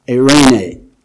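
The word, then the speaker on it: eirene (εἰρήνη): And the actual word, the actual Greek word here for peace is eirene. Now let me spell it with English letters for you. E-I-R-E-I-N-E-I. Eirene.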